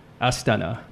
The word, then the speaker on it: Astana: Astana.